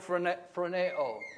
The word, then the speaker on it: phroneo: That's the word phroneo. And those who want that spelled phonetically, that would be spelled P-H-R-O-N-E-O. Phroneo.